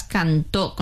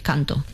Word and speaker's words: Cantó: Bueno, pues este año tenemos al representante Blas Cantó, con acento en la O hay que pronunciarlo bien, si no por ahí nos van a decir canto.